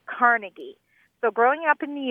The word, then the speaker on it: Carnegie: Yes, and if you go to his little hometown in Scotland, that's what you'll hear again and again. – Carnegie, accent on the second syllable. – With more of an A than an E.